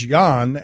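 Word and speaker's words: Jann: It's J-A-N-N. How would you pronounce that? – Go ahead. I thought it was Jan. – And okay, Jan. I didn't think about that. And W-E-N-N-E-R.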